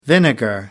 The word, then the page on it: vinegar: American English